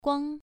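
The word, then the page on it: guang: tone 1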